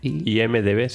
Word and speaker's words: IMDb: Lo dije bien, ¿no? – IMDb, sí. – IMDb. – Perfecto. – No, había hecho M-I-D-B.